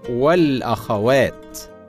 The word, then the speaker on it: الأخوات: من الكلمات المستخدمه في النداء قول المتحدث ايها الاخوه والاخوات هكذا تنطق الاخوه والاخوات بكسر الهمزه مع جمع التكسير المذكر الاخوه وفتحها مع الجمع بالالف والتاء الاخوات ولكن البعض ينطقهما بغير ذلك وهذا خطا شائع فالصواب ايها الاخوه والاخوات